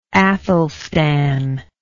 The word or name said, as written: Aethelstan